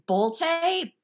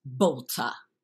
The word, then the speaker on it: Bolte: It's Bolte, but if we're really going to be mincy about it, it's actually pronounced Bolta in German.